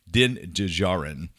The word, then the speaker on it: Din Djarin: Djinn Djarro? How do you pronounce his name? – Anyway, I don't know. – Mando, as Apollo Creed calls him, Din Djarin.